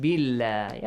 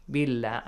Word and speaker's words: بالله: That whenever you have a kasra sound with the word Allah, how do you pronounce it? Heavily or lightly? – Heavily. – Heavily? Billah, do you say? – No, lightly. – Lightly, yes. Yeah. Billah, yes? Billah.